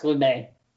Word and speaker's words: Lumet: Is it Sidney Lumet or Sidney Lumet? I never knew. – Lumet. I believe it's pronounced Lumet.